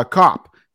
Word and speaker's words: Kopp: Today, I have Dan uh, Kopp.